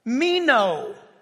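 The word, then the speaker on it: Meno (μένω): It's spelled like it sounds. Meno. M-E-N-O.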